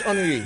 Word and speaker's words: Henry: Oh, Henry! Like French Henry.